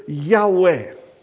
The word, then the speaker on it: Yahweh: It's pronounced something like this, Yahweh.